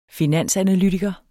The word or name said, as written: finansanalytiker